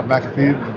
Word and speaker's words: McAfee: McAfee? – Yeah, McAfee, Pat McAfee show.